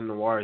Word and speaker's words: Noir: It's like Noir. Noir, I think that's how you pronounce Noir. – It because it's French.